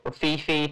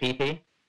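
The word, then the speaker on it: Phi Phi: Fifi, PP, however you want to pronounce it. – Oh, Fifi. – over there, they pronounce it PP.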